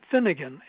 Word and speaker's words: Finnegan: Finnegan.